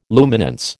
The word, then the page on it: luminance: American English